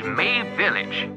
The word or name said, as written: Mabe Village